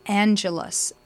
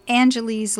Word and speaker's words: Angelus: And they are adding a guitar to it called the Angelus, not Angeles like Los Angeles, California. Angelus here is spelled A-N-G-E-L-U-S.